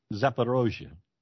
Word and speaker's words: Zaporizhzhia: A nuclear false flag on the Zaporizhzhia nuclear, I hope I pronounce it right, the nuclear power plant. – Zaporizhzhia. – Huh? – Zaporizhzhia.